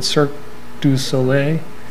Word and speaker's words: Cirque du Soleil: Or maybe to Cirque du Soleil, how do you say that word? Cirque du Soleil? Yes, one of those performing circus trapeze type things.